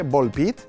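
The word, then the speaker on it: Ball x Pit: Tenim Absolum, de Warcrash Games, Supermonk, Dotemu, Volpit, encara que la X no es pronuncia, Volpit, de Kenny Sun, de Volver Digital, Blueprints, perdó, de Dogubomb i Raw Fury, Clair Obscur, em quedo amb això, vale?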